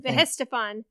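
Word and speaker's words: Weihenstephan: Vehenstephan has been brewing beer continually for nearly 1,000 years.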